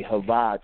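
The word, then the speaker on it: Chaya: And again, you go to the right-hand corner, upper right-hand corner, and you hit the drop-down Hava, the Hava channel, and it's spelled C-H-A-Y-A-H, but pronounced Hava.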